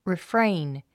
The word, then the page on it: refrain: rifréin; リフレェイン